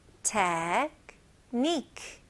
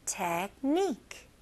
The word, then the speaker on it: technique: technique, technique. Okay, nique라고 N-E-E-K로 한번 생각해 보시고요. technique, technique.